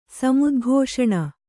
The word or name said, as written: ಸಮುದ್ಘೋಷಣ